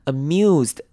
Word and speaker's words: amused: Amused 这边的 S 要发成 Z 的声音 amused